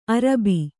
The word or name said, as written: ಅರಬ್ಬಿ